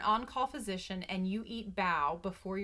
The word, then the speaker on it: bao: which are B-A-O, pronounced bao.